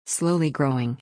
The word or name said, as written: slowly growing